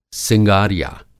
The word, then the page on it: सिंगारिया: Hindi